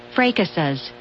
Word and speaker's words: fracases: Fracases. Fracases. – Okay, that's how it's pronounced.